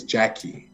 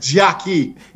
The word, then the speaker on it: Gyakie: Her name is Jackie, and it's spelled G-Y-A-K-I-E. – I know her. Yeah, I've heard of her, yes. – So she's definitely on my radar. That's how it's spelled. Jackie. Like Jackie.